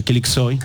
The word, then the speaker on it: Kılıçsoy: Kılıçsoy.